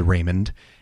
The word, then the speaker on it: Ramon: Now, Raymond actually is spelled not Raymond, as in everybody loves Raymond. It's actually R-A-M-O-N, which in theory might be pronounced Ramon, but it was and is Raymond.